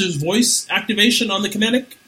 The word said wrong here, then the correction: Kinect: No, Kinect. It's spelled like it should be Kinetic, but it's pronounced Kinect.